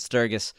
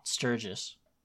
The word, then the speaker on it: Sturgis: But I would not see Bone Thugs and Harmony in concert at Sturgis. – It's Sturgis. – I don't really understand. But you're saying it wrong. Sturgis.